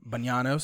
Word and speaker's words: Bagnano: It's Italian, Bagnano, so don't yell at me about that.